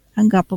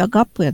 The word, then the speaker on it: агапе: Почему из всех слов именно вот это слово агапа было выбрано церковью, в принципе, агапе, да, вот если так точнее, как в греческом произношении, но у нас привыкли ставить ударение так, я уже буду говорить, как привычно нашему слуху, да, агапа было выбрано церковью, чтобы обозначить любовь Бога к нам и нашу любовь к Богу.